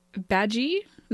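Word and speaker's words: Badguy: Yeah, this is Ricky Gervais, playing a character named Dominic Badgie. It's spelled a bad guy, but he tells them it's French.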